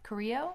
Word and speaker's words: Carrillo: Next match is Humberto Carrillo. – Carrillo. – Carrillo. – Spanish. – I can't see very far. – Don't pronounce the L's.